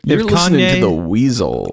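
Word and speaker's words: Weasyl: Furry, and Weasel, spelled with a Y, W-E-A-S-Y-L. – You said which, Weasel spelled with a Y, and I was like Yeezel? – Which I'm talking about.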